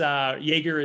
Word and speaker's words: Yeager: And it's Jaeger is Y-E-A-G-E-R. There's a couple of ways to spell it. And then I'm on Instagram, Facebook, you know, LinkedIn under my name, Russ Jaeger.